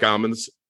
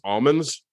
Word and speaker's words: almonds: "I like almonds," and he's pronouncing almonds wrong. He's like, and the other guy's like, you know, it's pronounced almonds. He's like, that's that said almonds.